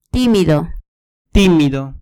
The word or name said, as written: timido